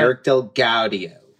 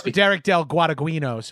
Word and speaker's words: Derek Del Gaudio: Derek Del Gaudio. – Yeah. I was going to say his name wrong, but that's perfect.